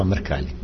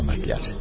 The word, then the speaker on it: Mrkalj: Treba reći, dakle, pre 192 godine Sava Mrkalj, tamo izgovara i Sava Mrkljar, negdje kažu Sava, ali Sava Mrkalj je zapravo prvi jovovski zaridao u svojoj pjesmi Jao, jao, jao, 300 puta.